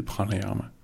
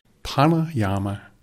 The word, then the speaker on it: pranayama: der hed pranayama. – Prøv lige at udtale det langsomt. – Pranayama. P-R-A-N-A-Y-A-M-A.